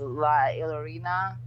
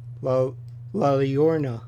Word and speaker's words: La Llorona: And the other one was, I want to do the pronunciation correct, but it was La Llorona. – Oh, yes. La Llorona.